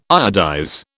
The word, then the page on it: iodize: British English